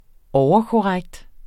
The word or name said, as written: overkorrekt